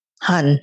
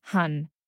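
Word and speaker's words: Han (한): Han. – Han. If you could root us in this conversation maybe by explaining what Han is and talking about why as a witch it's important to integrate Han in one's magic as much as the light, happy, joy, love vibes too.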